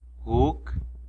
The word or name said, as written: guc